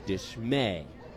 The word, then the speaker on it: Dishmey: Eliezer spelled E-L-I-A-Z-A-R. Dishmay, D-I-S-H-M-E-Y. But it's pronounced Dishmay.